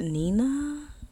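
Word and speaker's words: Nnena: I believe her name is pronounced Nena. It's N-N-E-N-A.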